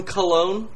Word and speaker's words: Cologne: I watched the press conference from Cologne, Germany. Did I say that right? – I think so. – But... – You did.